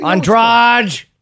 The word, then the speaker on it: Andrade: Say it like that. It's a fun name. You'll never not say that name that way. Andrade? – I'm not saying it like that.